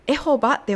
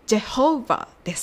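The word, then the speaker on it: Jehovah: エホバではなく、ジェホーバーです。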